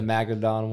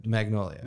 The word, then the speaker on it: Magnolia: Is it the Magnodon one? – Magnolia.